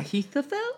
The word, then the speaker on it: Ahithophel: Ahithophel? – Yeah, Ahithophel. – Ahithophel. – So Ahithophel is like so wise, he's become one of the main guys. Like, what do we do, Ahithophel?